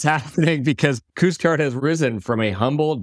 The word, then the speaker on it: Couche-Tard: To zoom out, Couche-Tard, which I did pronounce correctly, has also grown thanks to its willingness to experiment.